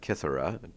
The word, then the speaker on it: Kythera: Chimes, if you will, the chimes of Kythera, to put it in its proper Greek pronunciation.